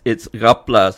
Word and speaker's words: Rapla: It's Gapla. So the actual spelling is R-A-P-L-A, Jinga, J-I-N-G-G-A, right? So the way we pronounce the R sound is, it sounds Ga, you know, and that's how in Penang, you know, right up to Perlis and all that, you know, you hear people pronouncing the R tone like that.